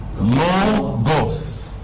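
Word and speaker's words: لوغوس (λόγος): يعني انت عندك كلمه مثلا، الكلمه دي بتتنطق لوغوس، لوغوس، تمام؟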